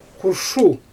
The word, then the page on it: xurʃu: Xyzyl